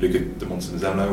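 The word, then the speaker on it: Luca di Montezemolo: Also, pronounce his name, Luca de Montezemolo, or whatever it's called.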